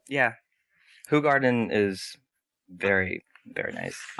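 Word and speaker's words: Hoegaarden: Yeah, it's spelled H-O-E, garden, so you assume it's ho. But it's actually right. Who garden? – I mean, it used to be...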